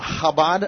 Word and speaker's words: Chabad: So is here from Chabad of Lake Havasu. Welcome back. – Thank you, and you pronounced Chabad perfectly.